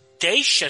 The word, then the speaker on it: Teague: Teich, así se pronuncia, ¿no? – Teich, ajá, sí.